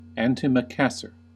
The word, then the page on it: antimacassar: American English